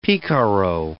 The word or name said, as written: picaro